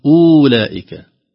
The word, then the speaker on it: أولئك: حتى شوف كلمة أولئك لو ما في هذا الصفر سنقول أولئك في واو لكن وضعناها لكن لا تنطق أبدا.